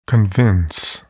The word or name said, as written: convince